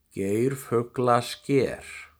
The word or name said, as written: Geirfuglasker